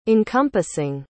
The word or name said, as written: encompassing